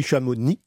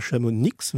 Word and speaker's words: Chamonix: Et il faut dire aussi Chamonix et pas Chamonix, même si les gens du coin continuent de prononcer un X.